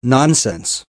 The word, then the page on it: nonsense: American English